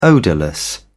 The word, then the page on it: odourless: British English